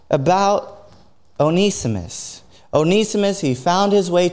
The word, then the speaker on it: Onesimus: His name is Onesimus. And again, it's not Onesimus, it's Onesimus, even though it's spelt with one these Greek names, Onesimus.